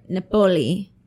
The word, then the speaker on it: Napoli: The earliest account of the vase dates back to the 15th century in a small town north of Napoli, Italy. I think I said that right. Napoli. I said it with an accent and everything.